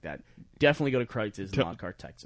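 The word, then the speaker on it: Kreuz: Go to Kreitz's, though.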